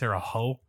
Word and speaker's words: Terre Haute: It's spelled Terre Hot, but it's pronounced Terre Haute. So that's a fun fact. – Like ho-ho, like Santa Claus.